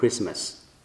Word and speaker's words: Christmas: Christmas 当中上是 Christ 后面加 mas， M-A-S，但是发音 I 改成 E 就变成 Christmas，所以圣诞节的时候是 Christ 诞生，就这意思叫 Christmas。